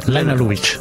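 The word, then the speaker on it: Lene Lovich: Sì, Lena Lovic anche. – Com'è? Lena Lovic. – Non era Lovic che si chiamavano. Era si pronuncia così, ah, perché okay, lei era di origine jugoslava.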